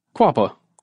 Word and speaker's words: Quapaw: Quapaw? – Quapaw.